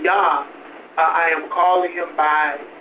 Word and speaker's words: Yah: It's spelled J-A-H, it's spelled Jah, but because in the Hebrew there is no J, it is spelled Yah. Okay, Y, you pronounce it with Y.